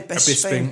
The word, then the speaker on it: Bisping: And then, uh, Bisping, is that how you pronounce his name? Bisping. Bisping. It was.